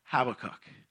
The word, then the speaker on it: Habakkuk: So to kind of start off, it is pronounced Habakkuk for me. And sometimes I will say Habakkuk because, you know, I feel like it should be an O-O there sound there. And it means embrace.